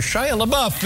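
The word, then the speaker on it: Shia LaBeouf: Шайя Лабаф. Боже мой, какой он симпатичный. – Шия, Шия, Шия, да. У него, у него это имя как-то произносится так. Шайя Лабаф, everybody.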